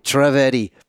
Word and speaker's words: Trivedi: Trivedi.